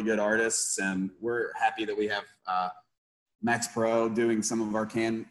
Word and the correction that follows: Perrott: Say his name again, Max. – Max Perot, Perrott.